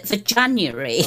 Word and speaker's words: Veganuary: Have I pronounced that correctly? – Well, I've called it Veganuary.